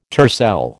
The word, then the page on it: tercel: American English